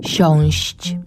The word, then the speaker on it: siąść: Inaczej niż na przykład w słowie siąść, gdzie słyszymy długie, długie ść, ść, siąść. Ale przecież branie nie ma z siedzeniem nic wspólnego. Dlatego też inaczej wymawiamy te słowa. Mówimy siąść długo, wziąć krótko.